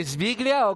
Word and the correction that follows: Siviglia: o cómo se dice? Siviglia? ¿Cómo es? Siviglia, muy bien.